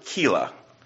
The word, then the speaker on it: Keilah: David comes to a city called, I think I'll pronounce it Keilah. K-E-I-L-A-H, right?